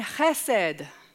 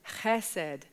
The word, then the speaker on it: chesed: chesed. Sometimes it's spelled with a C because it's got a guttural sounding H at the start. Sometimes it's written with it and without it, chesed.